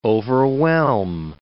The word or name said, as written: overwhelm